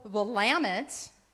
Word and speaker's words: Willamette: Our river is called Willamette. – Willamette.